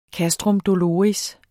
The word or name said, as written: castrum doloris